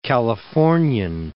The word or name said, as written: Californian